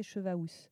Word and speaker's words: chevaous: Mais alors pourquoi, nom de Zeus Le pluriel de cheval était cheval avec un S, oui, A-L-S en ancien français. Le L s'est vocalisé en U, prononcé OU, et cheval a été orthographié chevaous. On disait chevaous.